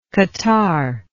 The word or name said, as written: catarrhed